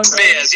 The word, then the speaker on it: Baez: I can't say her name. Baez? Is that what I'm saying that right? Baez? Joan Baez. Yes, I remember her well.